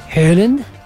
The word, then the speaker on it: Haaland: Bueno, y por otro lado, Erling Haaland, que los días me enteré porque entrevistaron a un noruego, ¿cómo se pronuncia Haaland? Ves que tiene doble A, ¿no? – ¿Haaland? – No, Haaland. – Haaland. – Haaland. Ellos es Erling Haaland. Bueno, Haaland. Para nosotros es Haaland.